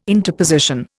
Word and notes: interposition: Indian

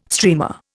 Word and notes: streamer: Indian

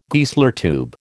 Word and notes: Geissler tube: American English

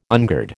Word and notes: ungird: American English

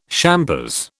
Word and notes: champers: British English

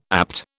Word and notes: APT: British English

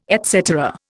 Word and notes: et cetera: Indian

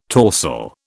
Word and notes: torsel: British English

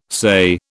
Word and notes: SAYE: British English

